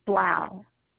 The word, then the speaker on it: Blau: Claire, C-L-A-R-E, Blau, B as in boy, L-A-U.